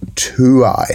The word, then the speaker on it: Tuai: So from approximately Ruatahuna is gravel, and it goes gravel all the way around Lake Waikaremoana to a place called Tuai, I believe. T-U-A-I, is that, am I pronouncing that correctly? Tuai? – I reckon you're pretty close on that one.